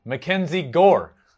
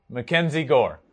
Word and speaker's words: Mackenzie Gore: Moving on to number four, Mackenzie Gore. It rhymes. Number four, Mackenzie Gore.